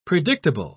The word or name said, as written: predictable